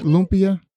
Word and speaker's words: Lumpia: How do you say it? Lumpia? – Lumpia.